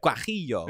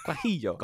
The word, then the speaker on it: Guajillo: How do you say this? You say it. – Guajillo. – Yeah, okay. – Guajillo. – I said that. I feel like I was saying it. Guajillo. – Guajillo.